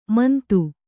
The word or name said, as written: ಮಾಂದು